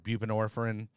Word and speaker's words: buprenorphine: And I've heard them put as like, it's a ceiling drug, you know, with Suboxone, AKA buprenorphine.